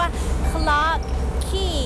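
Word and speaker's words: Clarke Quay: ก็จะมีสถานที่นะคะที่มีชื่อว่าคลาร์กคีสะกดหน้าตาแปลกๆนิดนึงนะ QUAY แต่คำนี้อ่านว่าคีเหมือนคีกุญแจละค่ะ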